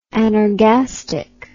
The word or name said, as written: anorgastic